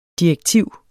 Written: DIREKTIVERNES

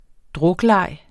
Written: drukleg

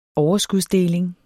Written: overskudsdeling

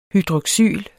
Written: hydroxyl

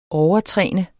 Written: overtræne

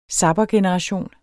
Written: zappergeneration

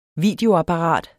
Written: videoapparat

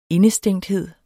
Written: indestængthed

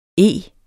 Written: ege

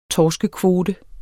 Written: torskekvote

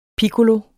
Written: piccolo

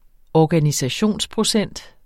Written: organisationsprocent

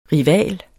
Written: RIVALS

